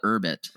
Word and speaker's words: Urbit: They call it Urbit. – Urbit. – That's how they pronounce it.